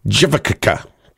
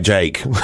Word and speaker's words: JVKE: I don't really understand this marketing, but Jake is J-V-K-E, which kind of makes it... I'm trying to pronounce it correctly. How do you pronounce that? Go on, tell me, how would you pronounce that? J-V-K-E. Jivakaka. Well, he goes by Jake.